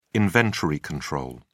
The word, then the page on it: inventory control: British English